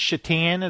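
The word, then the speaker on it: Šatan: Because his jersey says Satan, but it's pronounced Shatan.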